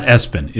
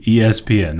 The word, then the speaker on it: ESPN: It's not Espen. It is literally ESPN. That's how you pronounce it.